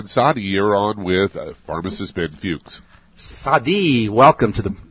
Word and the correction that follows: Sade: Is that S A D I? Am I saying that right, Sadi? – S A D E. – S A D E. Welcome to the Dead Doctors Don't Lie program, Sade.